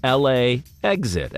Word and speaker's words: LAX-it: It is spelled LAX-IT. It's pronounced LA exit, as in fly into the airport and then make an attempt to exit the airport.